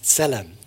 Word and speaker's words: tzelem: Okay, the first one is a Hebrew word and it's pronounced tzelem, T-Z-E-L-L-E-M.